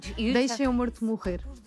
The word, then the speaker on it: Deixa-me Morto Morrer: It's in Portuguese, it's very hard to pronounce Portuguese, but it's almost. – No, you Deixa-me Morto Morrer.